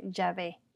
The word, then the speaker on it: Yave: En español se escribe y se pronuncia Yave Y-A-V-E.